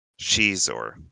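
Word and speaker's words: Scizor: Going back to the Zizor pronunciation. Imperial Ace 95 here in the chat says it's pronounced Shizor. – Yeah. – Like the female she.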